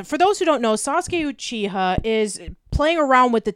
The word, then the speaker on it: Sasuke Uchiha: It's Sasuke Uchiha from Sasuke Uchiha. – Uchiha. Yeah, Sasuke Uchiha from Naruto.